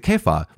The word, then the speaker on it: Kepha: It's C-E-P-H-A-S, which is Cephas, right? But Kepha, I think, is the one acceptable pronunciation of the name of Peter, or I belong to Christ.